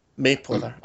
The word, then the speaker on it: Mapother: How did you bring in Clancy Brown and is it William Mapother? How do you pronounce that? Sorry. What was that? Sorry. – Mapother.